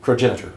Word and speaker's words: progenitor: Which is the progenitor race for the. – Progenitor race. – Progenitor, yeah. I put the emphasis on the wrong syllable.